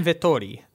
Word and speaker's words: Vettori: I think it's Vettori, but he's Italian, so it might be they say it in Italian. – Marvin Vettori. – Actually, legitimately that.